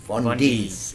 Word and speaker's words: Vondis: the owner of Vondis. Is that how you pronounce it? – That's it, Vondis.